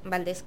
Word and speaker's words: Valdés: Mi nombre es María Antonieta Farías Valdés, Valdés con S y acento en la E.